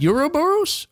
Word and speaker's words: Ouroboros: Oh, Ouroboros? – Yes. – Close enough. – Yep. – Ouroboros? – Yep.